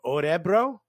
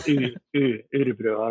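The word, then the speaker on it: Örebro: But that O with the two dots is like a U, I guess. Like, Orebro, I don't know, something like that.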